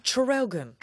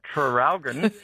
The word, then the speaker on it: Traralgon: Seeing as you asked about the spelling of Traralgon, could you please pronounce the first R? – Oh, Traralgon.